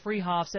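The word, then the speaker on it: Fruehauf's: They're spelled Freehoff's, pronounced Freehoff's, at 33rd and Arapahoe in Boulder.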